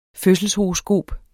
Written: fødselshoroskop

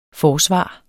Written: forsvar

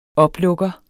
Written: oplukker

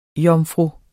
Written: Jomfruer

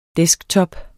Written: desktop

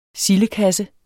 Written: sildekasse

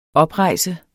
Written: OPREJSTE

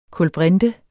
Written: kulbrinte

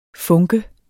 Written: funke

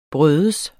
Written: bryder

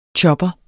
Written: chopper

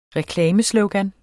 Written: reklameslogan

